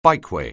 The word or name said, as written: bikeway